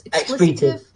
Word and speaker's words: expletive: Or maybe it's pronounced expletive. I don't know. It's expletive. That's the one you're looking for, expletive.